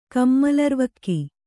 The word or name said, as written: ಕಮ್ಮಲರ್ವಕ್ಕಿ